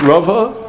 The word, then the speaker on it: Rava: So now, Rava, did I get the name right? Is it Rava? Yeah.